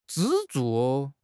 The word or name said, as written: 支柱